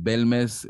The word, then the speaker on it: Bélmez: Belmes, Belmes eh, con acento en la primera E es este...